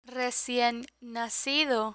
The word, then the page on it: recien nacido: Spanish